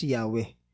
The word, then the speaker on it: Yahweh: Yung unang Panginoon doon or Lord na binabanggit, ang translation noon is Yahweh. Okay? Yahweh, which is yun yung tetragrammaton or sabihin nating four-letter name na pangalan ni God. Okay? – Di ba Jehovah? – Okay. Jehovah, yun yung... English na bigkas.